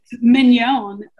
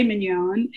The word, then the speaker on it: Mignon: It's Mignon, like filet mignon.